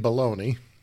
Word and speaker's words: bologna: Well, you know, I when I was a kid, I did work in a butcher shop, but we never made bologna. So, I mean, that's the way you would pronounce it in English, right? Even though it's spelled bologna.